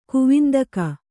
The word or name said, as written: ಕುವಿಂದಕ